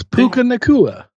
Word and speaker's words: Puka Nacua: But it's easy. It's Puka Nacua.